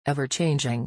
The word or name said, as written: ever-changing